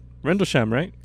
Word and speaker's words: Rendlesham: It's I brought, well, not me, but we brought Rendlesham Forest incident. – How do you say that? Rendlesham? – Rendlesham, right? – Yeah, like the Rendlesham incident.